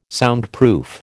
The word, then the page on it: Soundproof: American English